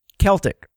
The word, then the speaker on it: Celtic: Is it Celtic or Celtic? – Celtic. – So he does like Celtic music. – Yeah, it's the Boston Celtics, but the word is Celtic.